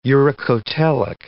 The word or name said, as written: uricotelic